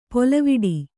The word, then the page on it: ಪೊಲವಿಡಿ: polaviḍi